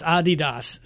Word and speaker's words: Adidas: Well, the name Adidas is made up of two people's names, and it's Adi, and I don't know what I don't know how it's the Das is part of the name, but it's Adidas is really the proper way to pronounce it.